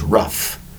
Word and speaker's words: rough: Today's word is rough. Rough, R-O-U-G-H. Notice the spelling of the word rough. For one syllable, it sounds like you would spell R-U-F-F. No, it's R-U-G-H. R-O-U-G-H is the correct spelling of rough. R-U-G-H, one syllable for the word rough.